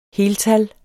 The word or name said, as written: heltal